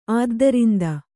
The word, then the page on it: ಆದ್ದರಿಂದ: Kannada